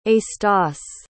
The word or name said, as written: estas